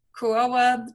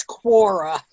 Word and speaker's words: Quora: And then you're going to go out and share it onto social media platforms, Pinterest, like you were saying, Kuroa. I never can pronounce that word. So forgive me. – I think it's Quora.